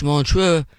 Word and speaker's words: Montreux: You know, we always say it wrong, Bonzo's Montreux. That's, it's Montreux.